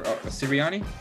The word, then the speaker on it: Sirianni: Shout out to Nick for Sirianni. Is that how you pronounce it? – Yeah, I don't want to mispronounce.